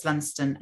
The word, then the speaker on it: Lundsten: So if anyone wants to find me, you know, you can search my name, Violet Lunston, and Twitter, I'll probably come up.